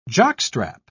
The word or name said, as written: jockstrap